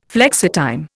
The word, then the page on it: flexitime: Indian